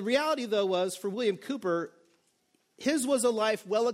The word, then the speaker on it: Cowper: William Cowper is a famous English poet from the 1700s. His last name is spelled like Cowper, C-O-W-P-E-R, but it's pronounced Cowper.